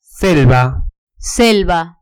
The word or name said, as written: selva